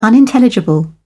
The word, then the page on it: unintelligible: British English